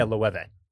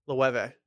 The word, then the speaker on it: Loewe: And no, oh yeah, Loewe. – Loewe. – Yeah. – It's almost like Nueve in Spanish for 9.